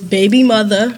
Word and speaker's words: BBYMUTHA: Her name is Baby Mother.